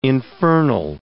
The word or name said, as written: infernal